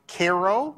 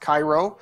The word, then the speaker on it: Cairo: Uh, even today, southern Illinois is called Egypt, and you see cities like Cairo, not pronounced Cairo. If you go down there, the very few people that are left down there will, will correct you.